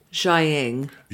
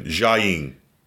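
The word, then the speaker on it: Jiaying: But so we see that basically the mom says that she help me with the pronunciation here because I keep butchering it. Sky's mother, Xiaying. – Xiaying. – Xiaying. – Xiaying. – Ing. – Ing. Ing. – I-N-G.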